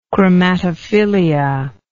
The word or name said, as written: chromatophilia